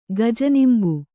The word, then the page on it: ಗಜನಿಂಬು: kannada